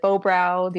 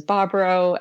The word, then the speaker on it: Bobrow: So like half my family pronounces it Bobrow. The other half does Bobrow. And I say both. I use both pronunciations and confuse everyone.